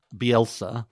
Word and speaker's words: Bielsa: Bielsa, is that how you pronounce his name? – That's his name, yes.